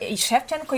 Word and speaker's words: Shevchenko: Yeah, Shevchenko you must pronounce correctly, because it was such a famous Ukrainian football player, Andriy Shevchenko.